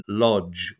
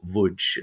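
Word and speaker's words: Łódź: So the first visual art gallery, as we understood it today, was in Poland in Lodz, or Wódź, as it's pronounced there.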